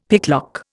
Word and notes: picklock: Indian

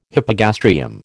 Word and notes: hypogastrium: American English

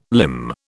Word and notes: limn: British English

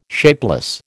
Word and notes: shapeless: American English

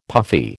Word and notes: puffy: American English

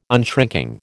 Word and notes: unshrinking: American English

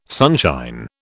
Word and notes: sunshine: British English

